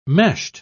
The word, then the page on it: meshed: British English